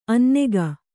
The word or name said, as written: ಅನ್ನೆಗ